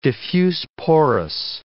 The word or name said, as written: diffuse-porous